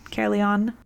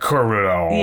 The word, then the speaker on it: Caerleon: how would you pronounce that? Caerleon? Caerleon? – If it's Welsh, it's probably like Caerleon.